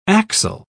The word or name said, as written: axle